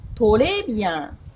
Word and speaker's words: très bien: このフランス語特有の発音。レ、レ、このレの音。トレビアン、レ、レという風にちょっと難しいと考える人は結構多いんですけど。もうちょっと一歩踏み込みましょうか、今日は。ト、レ、ビアン。